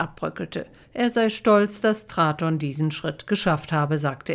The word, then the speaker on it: Traton: Jetzt also neuer Anlauf für Traton, wie es sich schreibt, oder Traton, wie es im Konzern ausgesprochen wird.